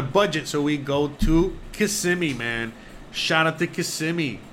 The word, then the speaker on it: Kissimmee: Kissimmee. That's how you say it right.